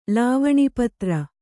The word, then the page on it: ಲಾವಣಿಪತ್ರ: Kannada